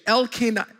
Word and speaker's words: Elkanah: It's interestingly enough, Samuel's father, I'll give you the pronunciation, Elkanah, and his mother, Hannah.